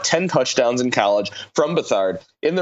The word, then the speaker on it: Beathard: Beathard, B-E-A-T-H-A-R-D, which is not said how it's spelled.